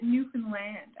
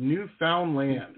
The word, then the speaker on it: Newfoundland: You pronounce it Newfoundland, actually. – Like, understand. – No. Yeah. Newfoundland. Okay, got it.